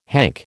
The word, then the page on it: hank: American English